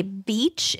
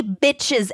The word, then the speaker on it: bitch: So the instructor, her name is Ronnie, talks about how ESL learners often say beach instead of bitch. Mm. So she suggests the following for pronunciation practice. Repeat after me. – Okay. – There were many bitches at the beach.